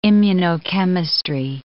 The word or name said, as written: immunochemistry